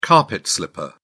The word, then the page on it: carpet slipper: British English